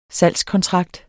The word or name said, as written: salgskontrakt